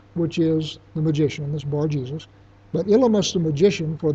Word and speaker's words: Bar-Jesus: And by the way, I'm pronouncing him Bar-Jesus, not Bar-Yeshua, because this is Greek, and his name may in fact have been pronounced Bar-Jesus as opposed to Yeshua.